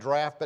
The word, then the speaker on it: draught: That word does not look like it's supposed to be pronounced draft, but that's what the English people say.